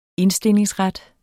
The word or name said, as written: indstillingsret